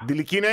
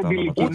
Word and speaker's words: Ντιλικινά: Ντιλικινά είναι. – Όπως το λέει. – Ναι, ναι, ναι. Ντιλικινά. – Α, ναι. Ούτε ναι, δηλαδή ναι, ναι, τονίζεται στη λίγουσα.